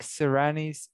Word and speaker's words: Sirianni: I think you say Sirianni. I'm pretty sure that's how you say his name.